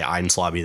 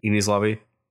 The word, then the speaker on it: Enies Lobby: It's also funny because the only reason I know it's pronounced Aang's Lobby, because it looks E-N-I-E-S in the Viz translation, like Eni's Lobby. But I've just always heard people say Aang's Lobby this, Aang's Lobby that.